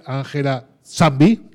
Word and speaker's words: Ángela Nzambi: Para empezar, nos visita esta tarde Ángela Zambi, lo he dicho bien. Sí, está Lo he dicho bien. Ahí está.